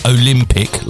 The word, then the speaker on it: Olimpick: It is the Cotswold Olympic, and it's spelled O-L-I-M-P-I-C-K, so they say Olympic, like that, Olympic right.